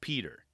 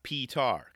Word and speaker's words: Petar: And I was hoping to hear him say his name because I think it's pronounced Peter, not P-tar, because his name is spelled P-E-T-A-R. Only because, like I said, there's an A in there.